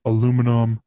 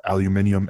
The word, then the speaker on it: aluminium: everything is aluminum or aluminium as he pronounces it and a lot of the british people pronounce